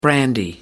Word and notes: brandy: American English

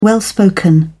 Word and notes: well-spoken: British English

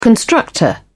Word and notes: constructor: British English

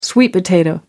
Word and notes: sweet potato: American English

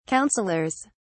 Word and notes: counselors: British English

